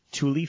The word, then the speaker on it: tulle: They sell a 54-inch Tule fabric. – Tule. – I can't win. T-U-L-L-E. Tule fabric. Not like T-O-O-L, which is how I pronounce, or I spell the word Tule, but whatever.